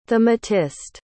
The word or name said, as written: thematist